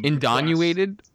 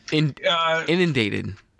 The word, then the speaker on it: inundated: I won't mention him because I do not want Indonuated? What the hell is indonuated? – How do you pronounce it? – Inundated.